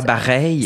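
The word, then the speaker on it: Bareilles: Euh, ben, sur place, j'avais chanté euh, Gravity de Sarah Barrow. Je sais pas comment on prononce ça. Barreille. – Sarah Bareil. Sarah Barreille, Bon, et elle, j'avais fait que j'avais chanté ça.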